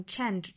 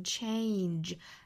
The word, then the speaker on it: change: 我们注意 change 这个单词是双元音 a 的发音，一定不要看了这个单词的写法是这个 ange 就读成了 change，那读成 change 就错了，大错特错哈，应该读成 change a 的发音 change。